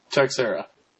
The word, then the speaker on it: Teixeira: Teixeira.